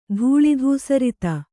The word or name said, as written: ಧೂಳಿಧೂಸರಿತ